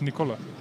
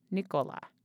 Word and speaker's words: Nikola: Okay, so emphasis not on any particular syllable. Nikola.